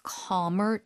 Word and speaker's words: calm: If the mom stays calm, 엄마가 좀 안정이 되면 the child feels calmer too. 아이도 그만큼 더 안정이 되죠. – 그렇죠. calm 발음 좀 도와주세요. 비교 끝까지. – calm. L 발음은 거의 그냥 하지 않는다라고 생각하시고 길게.